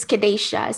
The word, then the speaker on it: Kadasha: It's Kadasha. So it's Kadasha. A long A.